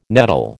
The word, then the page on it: nettle: American English